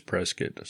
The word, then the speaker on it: Prescott: And we're calling it Prescott. We used to call it Prescott, and we hear a lot of people call it Prescott. But we were corrected down there by a local saying it is Prescott.